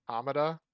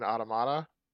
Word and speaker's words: Automata: Automata? You mean Automata? – What did I say? Automata? – Automata. I've never heard it pronounced like that.